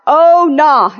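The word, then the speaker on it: Ownah: It's O-W-N-A-H, but it's pronounced oh nah, and I think oh no.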